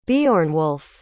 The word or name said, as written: Beornwulf